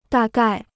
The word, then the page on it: 大概: dà gài